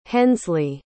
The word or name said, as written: Hensley